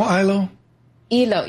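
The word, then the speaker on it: Iloilo: I think I pronounced it. It's Ilo Ilo? – Ilo Ilo. – Ilo Ilo, thank you.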